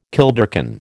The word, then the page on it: kilderkin: American English